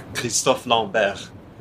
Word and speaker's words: Christophe Lambert: Christopher Lambert, right? You know, Yeah. – It's we pronounce his name Christophe Lambert.